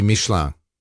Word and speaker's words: Michelin: By the way, because you're into France and all that, how do you say Michelin in French? – Be Michelin. – Ah, Michelin. – It's I-N, sort of like an, you know, like your, you know, it's like an N-G in a way.